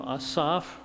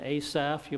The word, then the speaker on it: Asaph: You may be more familiar with Asaph. Well, I'm simply pronouncing his name as it's pronounced in the Hebrew. There is no hard A in Hebrew. Therefore, his name is Asaph, not Asaph.